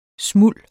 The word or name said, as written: smulds